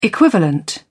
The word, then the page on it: equivalent: British English